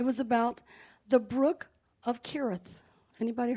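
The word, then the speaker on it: Cherith: And right above the Jordan River is a brook, and it's called the Brook of Kirith. It's spelled with a C, but it's pronounced with a K.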